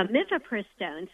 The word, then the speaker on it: Mifepristone: Yeah, Mifepristone.